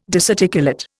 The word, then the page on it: disarticulate: Indian